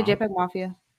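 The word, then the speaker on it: JPEGMafia: Yeah, JPEG Mafia.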